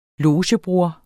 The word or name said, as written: LOGEBROR